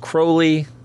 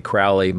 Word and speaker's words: Crowley: Also, just a quick note, I believe his name is pronounced Crowley, as Robert Anton Wilson constantly reminds me. It's supposed to rhyme with holy.